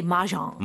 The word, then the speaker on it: Mahjong: It's M-A-H-J-O-N-G. And Americans usually say Mahjong.